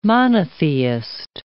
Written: monotheist

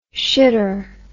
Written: shitter

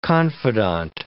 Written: confidant

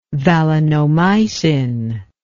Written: valinomycin

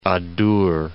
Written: Adour

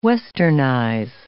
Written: westernize

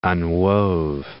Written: unwove

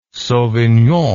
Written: sauvignon

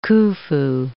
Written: Khufu

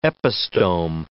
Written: epistome

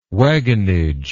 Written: wagonage

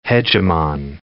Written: hegemon

